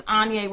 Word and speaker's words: Onya: My name is Onya, rhymes with Kanye.